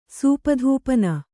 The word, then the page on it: ಸೂಪಧೂಪನ: Kannada